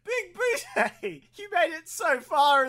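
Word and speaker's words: Bigbooté: And it's at this point which John Lithgow says Big Booty and pronounces it Big Booty one too many times, and John Big Boutte loses it. And he's like, it's Big Boutte, Big Boutte.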